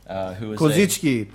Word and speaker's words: Kozicki: Kozicki, because I'm using correct pronunciation today, because we are doing Eastern Bloc game.